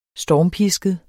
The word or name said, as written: stormpisket